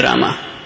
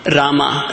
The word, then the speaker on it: Rama: Rama should rhyme with Rama. Rama, not Ram. It is Rama.